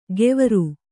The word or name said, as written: ಗೆವರು